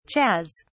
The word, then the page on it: chas: British English